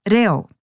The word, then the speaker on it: reo: Now for the part that many people find harder, reo. So if you know that Māori vowel sounds are a, e, I, o, u, bring that knowledge to the word, reo, reo.